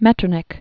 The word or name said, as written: Metternich